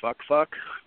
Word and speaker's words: Fak Fak: So, yeah, there's a place called Fak Fak. It's pronounced Fuck Fuck.